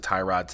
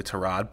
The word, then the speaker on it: Tyrod: Yes, I've heard it pronounced even both ways, and Sean McDermott, I think, also pronounced it Tyrod today incorrectly. So I'm not sure if it's completely gotten to everyone that his name is really Tyrod, but you know, I'm not mad.